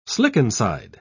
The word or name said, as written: slickenside